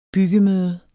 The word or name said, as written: byggemøde